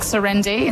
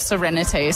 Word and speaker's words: Serenity: Like, I'll say it too fast. So I'll be like serenity. Like serendi instead of serenity.